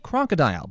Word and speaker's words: Krokodil: Lieutenant Brad Dunlap is the head of the Lake Winnebago Area Meg Unit, and he says it's known as Crocodile, but it's spelled K-R-O-K-O-D-I-L.